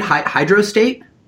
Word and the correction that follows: hydrolysate: I say hydrolysate.